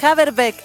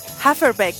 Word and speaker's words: Haferbeck: No es Haverbeck, es Haferbeck. Me voy a olvidar. Haferbeck. Bueno, debería escribirse con F entonces, pues. Me dice aquí don Claudito que la B corta, o V, se pronuncia como F.